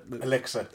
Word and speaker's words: elixir: Exilia of Life. Is that how it's pronounced? You know that... – Elixir. – Elixir.